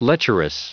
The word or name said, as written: lecherous